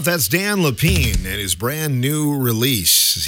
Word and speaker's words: Lepien: If you want to check him out, it's Dan Lapine, and Lapine is spelled L-E-P-I-E-N.